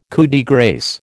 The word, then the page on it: coup de grâce: American English